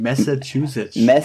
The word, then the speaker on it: Massachusetts: Meine Familie, also die erste Familie, war in Newton, Massachusetts. Das ist in der Nähe von Boston. – Kannst du den Staat nochmal sagen? Ich kann den natürlich nicht aussprechen. Massachusetts.